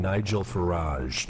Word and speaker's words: Nigel Farage: And the leave faction was led by a guy named Nigel Farage.